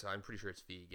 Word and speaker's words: Feig: Are you sure that's how you say Feig? – I'm pretty sure it's Feig. Yeah. – I've been saying Feig all these years.